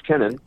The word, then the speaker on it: Kennan: Sorry, George Kennan? – Yes. Kennan, sorry. I'm sorry. I said it wrong.